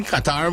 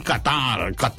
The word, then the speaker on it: Qatar: Hey, Wayne, they play in Qatar. Oh, as the Americans would say, Qatar. Every American pronounce Qatar. They say Qatar.